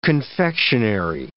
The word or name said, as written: confectionery